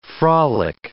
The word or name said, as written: frolic